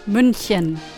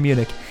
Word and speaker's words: München: Munich. – München. – München. Yeah, Americans say Munich.